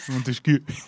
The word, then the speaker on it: Montesquieu: Montesquieu. Pero lo ha dicho así porque hay que pronunciarlo así.